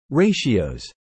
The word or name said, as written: ratios